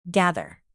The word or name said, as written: gather